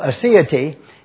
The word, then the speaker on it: aseity: And so aseity is the way it should be pronounced, and I've written it out like this, and we'll emphasize this again on Sunday. I put A-S-E-E-I-T-Y, aseity.